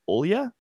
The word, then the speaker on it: Olya: Also, we need to make a decision because I looked up a few of the names in Russian to see how they're pronounced, and Olga is actually Olya.